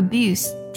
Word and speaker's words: abuse: 而 abuse 这个单词它可以做名词，也可以做动词。那做名词去讲的时候呢，呃，abuse 它的尾音呢是读作嘶的音的，它指的是虐待、辱骂和恶语相向的意思。所以 verbal abuse 则指的是语言暴力了。我们看一下这个句子啊，This is the second time Kate has been accused of verbal abuse。